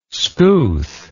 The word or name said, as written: skouth